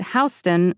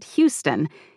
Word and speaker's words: Houston: If Houston Street in New York City is mentioned, I need to know to say it Houston, not Houston, even though it's spelled the same as Houston, Texas.